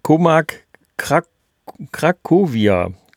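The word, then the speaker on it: Comarch Cracovia: Komag Krakowia, glaube ich, wird das ausgesprochen. Sehr gut, sehr gut ausgesprochen.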